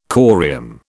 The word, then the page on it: corium: British English